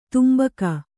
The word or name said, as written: ತಂಬಾಕು